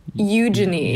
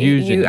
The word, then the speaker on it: Eugenie: It's so not how I've been saying it all along. It's Eugenie. – Eugenie. I think you emphasize the first part of